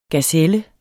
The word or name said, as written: gazelle